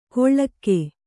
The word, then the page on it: ಕೊಳ್ಳಕ್ಕೆ: koḷḷakke